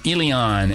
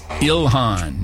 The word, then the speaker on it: Ilhan: Austin, mispronouncing Ilion, adding an extra little country Y. It's Ilhan, not Ilion. Han.